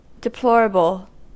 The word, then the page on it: deplorable: American English